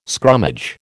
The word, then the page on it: scrummage: American English